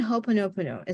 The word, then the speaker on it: ho'oponopono: yeah you mentioned ho'oponopono, is that, did I say that correctly? – Mm-hmm. – Mm-hmm. Okay. I wanted to make sure I said it correctly.